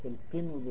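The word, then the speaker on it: قنو: تنطق القن بكسر القاف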